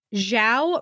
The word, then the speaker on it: Zhao: Chinese ZH is like English J, and Zhao rhymes with now, but with a J.